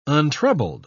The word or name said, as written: untroubled